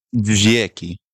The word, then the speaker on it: dźwięki: dźwięki. Dźwięki. – Dźwięki? – Dźwięki. – Dźwięki. – So a right. W in Polish is a V. – Okay. – Or pronounced as a V. So dźwięki.